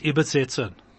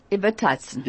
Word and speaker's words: übersetzen: So I'm going to talk in English today, and ihr wird übersetzen. – Übersetzen. Übersetzen. – Nein, übersetzen ist richtig gesagt. Okay.